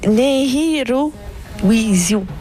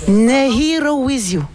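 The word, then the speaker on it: Nehirowisiw: Il dit Nehirawisi. Nehirawisi. – Nehiro with you. – Exactement. – Bon, alors, Nehiro with you, c'est comme ça que ça se prononce.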